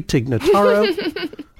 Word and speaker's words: Tig Notaro: Tig Notaro. Notaro. I'm sorry. Yes, she's probably one of my favorites. – We'll just say for the record that you said her name correctly, Tig Notaro.